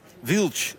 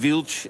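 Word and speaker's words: Wild: Wilch, zeg je dan, hè, op zijn Portugees. – Zoals jij dat alleen maar kan, hè. – Ja, precies. Die Wilch is net zoals Fretsch. Die D spreek je dan zo uit.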